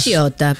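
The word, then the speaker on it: Pisciotta: Lauren Picciotta. ¿Cómo se pronuncia eso, chicas? – Picciotta. – Picciotta, como Capritini.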